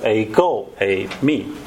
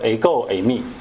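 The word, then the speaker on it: ego a me (ἐγώ εἰμι): 可能大家常常听到牧师会讲到这个词哈，叫做 ego a me，这是希腊文的发音啊，常常就讲说 ego a me，这是什么意思呢？就是我，我就是。那当然这个词并不是耶稣专有的词，本来希腊文里面就说，哎，我就会说 ego a me 啊。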